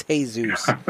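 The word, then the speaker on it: Jesús: It's Jesus. Spelled the same, pronounced different, Jeff.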